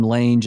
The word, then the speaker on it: Lange: So first of all, we could spend an hour talking about how you pronounce L-A-N-G-E. Is it range with an L? Or you've been pronouncing it like Lang, like Katie Lang. – Yeah, I have always pronounced it Lang until I heard you say Lange. And then I thought, you must know something I don't know. So I switched.